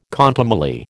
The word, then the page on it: contumely: American English